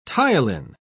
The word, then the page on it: ptyalin: British English